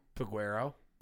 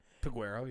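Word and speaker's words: Peguero: Piguero. – Piguero? Did I say that right? Yeah. – Piguero, yeah.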